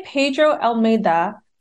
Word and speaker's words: Pedro Almeida: And today, Pedro Almeida, CEO and co-founder of MindProber, is joining me.